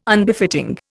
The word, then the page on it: Unbefitting: Indian